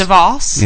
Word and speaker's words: DeVos: Is that how you say it? I think DeVos. – DeVos? – Yes.